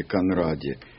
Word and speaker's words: Конради: Ударение фамилии Конради на А.